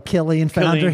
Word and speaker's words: Killeen Foundry: Currently has the band, I like to say, pronounce it Kyleen Foundry. I think it's probably Killeen Foundry.